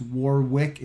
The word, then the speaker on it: Warwick: He is Australian, so there's a silent W in his name. It's Warwick. It's spelled W-A-R-W-I-C-K, but pronounced Warwick, at Warwick Fairfax.